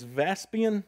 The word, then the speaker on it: Vespasian: General, I'm going to say it is Vespasian, is how you say his name.